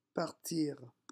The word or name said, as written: partir